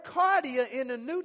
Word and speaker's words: kardia: spelled K A R D I A, which is pronounced cardia, which is where we get our English word cardiac from.